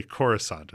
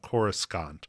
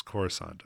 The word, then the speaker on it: Coruscant: And yet it's just understood that this word that is spelled Coruscant, that we don't pronounce that middle C, that we just say Coruscant. It's not Coruscant. We just know it's Coruscant, and it's interesting.